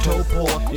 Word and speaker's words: Taupō: It's pronounced Taupō, you gotta think on your feet.